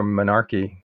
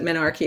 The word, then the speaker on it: menarche: Could you talk a little bit about the indigenous ceremonies for marking I'm not sure if you pronounce it menarch or menarche. – I like menarch, but there's a big debate about this, but menarche is also in use.